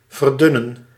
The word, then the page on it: extenuate: [ɪkˈstɛn.jʊˌeɪt]; /ɪkˈstɛnjueɪt/